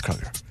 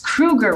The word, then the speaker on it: Kroeger: It was confirmed by Chad himself, who said just so many people said it wrong for so long, he was just like, fine, it's Kroger. But it's Chad Kroeger. So here is Chad Kroeger being interviewed on Loud Wire Nights and given the real pronunciation of his name. – We are catching up with Chad Kroeger, frontman for Nickelback. And it is Kroeger, right? Because you know 50% of the internet says Kroger.